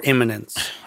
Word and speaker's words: imminence: Did you say eminence or imminence? – I probably mispronounced that. – Imminent. – Imminence. – Imminence. Okay. – Yeah, not eminence, but imminent.